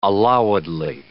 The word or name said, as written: allowedly